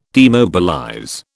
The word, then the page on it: demobilize: British English